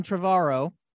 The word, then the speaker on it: Trevorrow: So This one is directed by the director of Jurassic World, Colin Trevorrow, rhymes with tomorrow.